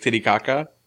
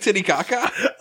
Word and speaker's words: Titicaca: Titicaca. – What? – Are you not familiar with Lake Titicaca? – Nope. – I can't really parse out the syllables. – Kitty caca. – Kidding caca? – T-I-T-I-C-A-C-A.